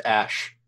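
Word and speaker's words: Æ: That's pronounced Ash.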